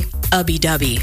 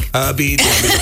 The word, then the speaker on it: Ubby Dubby: It was, what Mark said, Ooby Dooby. It's actually Ubby Dubby. – Ubby Dubby, excuse me.